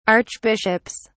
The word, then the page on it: archbishops: British English